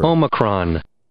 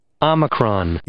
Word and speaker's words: Omicron: Omicron. Omicron.